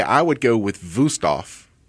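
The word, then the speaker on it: Wüsthof: Wusthof. Yeah. Looks like Wusthof. Pronounce Wusthof.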